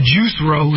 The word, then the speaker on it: Juicero: So from 2014 to 2017, you can look this up, by the way, Juicero, spelled like juice, R-O. Or juice row, if you want to do it that way.